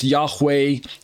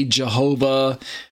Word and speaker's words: Yahweh: YHVH, otherwise pronounced Yahweh, Yahweh, Jehovah, Yahweh, Hashem, Adonai, different pronunciations there, but the Lord our righteousness.